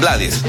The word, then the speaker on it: Blades: él acepta que le digan Blades, pero su apellido realmente se debe pronunciar como Blade.